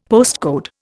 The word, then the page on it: postcode: Indian